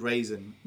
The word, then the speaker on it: Rasen: It was called Raisin, not as in the toast. – not as in Raisin Toast. R-A-S-E-N is how it's spelled.